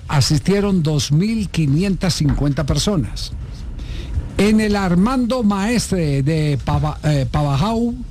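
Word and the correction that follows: Pavajeau: Es Pabayú. – Sí, se pronuncia Pabayó. – Pabayó, ¿cómo? ¿Pabayú o Pabayó? – Pabayó.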